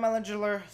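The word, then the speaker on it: Ehlinger: Sam Ellinger, that's how you say it.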